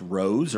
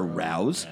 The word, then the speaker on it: Rao's: I guess it's pronounced Rose or Rouse. – R-A-O. – R-A-O-S.